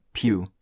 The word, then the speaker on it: Pugh: Pugh 是演 Amy。